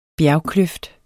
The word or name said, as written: bjergkløft